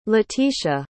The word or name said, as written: letisha